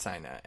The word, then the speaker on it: Sina: I listened to a podcast, okay, and it's pronounced Sina.